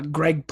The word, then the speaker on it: Gregg: Greg Playing Games. So G-R-E-G-G.